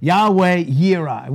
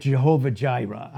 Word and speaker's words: Yahweh Yireh: all He wanted to be was Yahweh Yireh. We had Jehovah Jireh. I'm just going to pronounce it the right way. Yahweh Yireh.